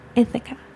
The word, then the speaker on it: Ithaca: Ithaca. – Ithaca, yes.